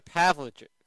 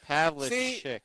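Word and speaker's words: Pavlovic: Yeah, so by the way, real quick, it's Sasha Pavlovich. – There you go. – It's Pavlovich.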